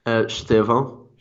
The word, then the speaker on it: Estêvão: Estevao. – Estevao. – It's weird. – Well, I think it's the E at the beginning that throws me off.